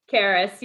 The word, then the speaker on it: Karas: Karas, you got it close, okay. Really close. – I like saying it with rolling the R.